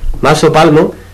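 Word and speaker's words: Mas Sopalmo: Antoni Miró, que por cierto, vive rodeado de arte, porque veía su lugar de trabajo y también de residencia, Maso Palmo, no sé si lo he pronunciado bien. – Muy bien.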